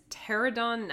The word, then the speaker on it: Pterodon: Yes, our next card is Pterodon Knight, the silent P there.